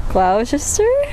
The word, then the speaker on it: Gloucester: Gloucester.